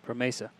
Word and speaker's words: Promesa: So the difference, Randy, I think, but with the La Promesa, Is that how you say it? – Promesa.